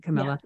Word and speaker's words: Camilla: And is that how you properly say her name? Camilla? – Yeah.